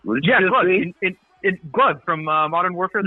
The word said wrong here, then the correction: Gulag: Okay, it's pronounced Gulag. Right? – Are you serious? – It's the damn Gulag.